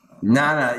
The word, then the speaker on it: Jnana: That's really what Jnana Yoga, I don't know how to pronounce that, Jnana Yoga. – Nana.